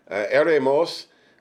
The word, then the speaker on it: eremos: The Greek word is eremos, and I don't know why I pronounce Greek words like I'm speaking Spanish. I don't know, because Spanish is the language, other language that I kind of speak, but it's spelled E-R-E-M-O-S, eremos.